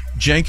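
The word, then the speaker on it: Cenk: Jenk is tough spelling. It's C-E-N-K, right? It's a Turkish name. The C is pronounced like a J.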